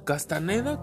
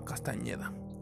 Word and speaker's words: Castañeda: Eh, empezar con la saga de audiolibros de Carlos Castañeda o Castaneda, como se traduce en sus libros, bueno, más bien como se escriben sus libros, pero eh, realmente se llamaba Castañeda.